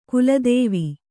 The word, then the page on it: ಗೋಲಿತೇವು: kannada